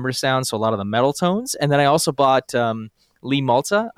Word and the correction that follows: Malia: I hope I'm pronouncing that right. I don't think I am. – Malia. Lee Malia.